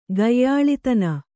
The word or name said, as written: ಗಾಯಾಳಿತನ